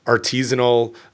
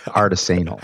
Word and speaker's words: artisanal: She just recently finished her PhD, Stephanie Maggio, who worked on an online course for artisanal cheesemakers and focusing on. – I think it's pronounced artisanal. – I think you're right. I think I said that wrong.